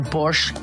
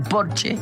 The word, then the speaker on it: Porsche: O decir que tengo un Porsche en lugar de decir tengo un Porsche, porque en alemán Porsche se dice Porsche porque todas las es al final se pronuncian, como Marlene Dietrich, por ejemplo, que todo el mundo dice Marlene, pero es Marlene.